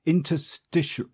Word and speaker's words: interstitial: Welcome to episode 56 of the Digital Marketing Tips Podcast, where we're talking about interstitials.